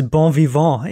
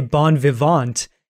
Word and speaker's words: bon vivant: You pronounce bon vivant in the French manner, but you know, of course, you would have to say bon vivant if it's going to rhyme with what we want. And, you know, I mean, there are English speaking people who do pronounce it that way.